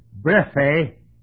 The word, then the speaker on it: brephe: I mispronounced it a minute ago. It's brephe, B-R-E-P-H-E, brephe, brephe. Last one is short.